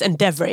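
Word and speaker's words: Endeavour: Det må jo uttales Endeavor, ja. – Ja, Endeavor. Ja. Jeg sa feil.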